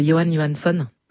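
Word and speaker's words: Jóhann Jóhannsson: Ouais, c'est Johan Johansson.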